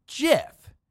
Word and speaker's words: GIF: Half of you are going to fight me on this, but the guy that invented them has flat out said that it is pronounced GIF, as a tribute to GIF peanut butter.